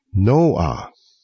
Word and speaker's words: Noah: 听众朋友，您不要以为这是说笑啊，您知道挪亚这个名字在英语系统里怎么念吗？ Noah，两个音节。